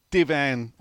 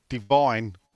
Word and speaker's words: Divin: Thoughts on Divan or Divine, as I'm supposed to, I think, pronounce it, even though there's no E. So, Divin.